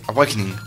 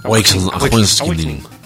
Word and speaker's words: Awakening: Link's Awakening, lo he dicho bien. – Sí, Awakening. – Awakening. – Efectivamente. Así está bien pronunciado.